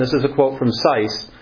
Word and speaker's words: Seiss: Well, is Sykes with a K or a T? – It's with an S. It's S-E-I-S-S. J.A. Seiss. – Spell it again. – S-E-I-S-S.